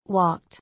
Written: Walked

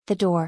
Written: The door

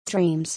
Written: Dreams

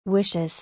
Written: Wishes

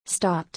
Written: Stopped

